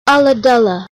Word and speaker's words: Ulladulla: And he just wanted to clarify the pronunciation of Ulladulla. You know what? He gave us a video which showed us the pronunciation, and I'm still pretty sure I got it wrong. – Here it is now. – Ulladulla. – That's how you say it.